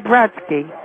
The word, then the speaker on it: Brodsky: I said Brodsky. – How do you spell that? – B-R-O-D-S-K-Y. – B-R-O-D-S-K-Y. – No, S-K-Y. – S-A-Y.